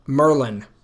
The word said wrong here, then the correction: melyn: It's melon.